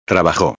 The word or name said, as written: trabajo